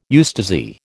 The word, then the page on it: eustasy: American English